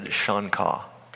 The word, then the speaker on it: Xonca: It's a little town called Shonkaw. It's spelled X-O-N-C-A, and it said Shonkaw.